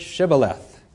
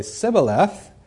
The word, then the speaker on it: Shibboleth: If he answered no, they would tell him, "Say the word Shibboleth." If the fugitive would say Sibboleth, because he couldn't pronounce the word correctly, I guess they couldn't say the SH, or whatever it was here in Hebrew,